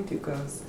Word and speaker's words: Ducos: Just call me Ducos.